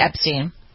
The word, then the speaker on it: Epstein: Is it Steen or Stein? I don't know how to pronounce that. It's Epstein.